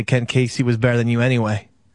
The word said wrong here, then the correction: Ken Kesey: Well, why don't you pronounce the name properly? – Ken Kesey.